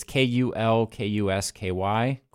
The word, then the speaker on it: Kulkusky: It's K U L K U S K Y, Kulkusky.